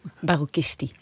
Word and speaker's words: Barocchisti: Zeg ik het nou goed, Jet? – Barocchisti. – Barocchisti. Ja, ik moet het wel goed uitspreken. – Heel belangrijk.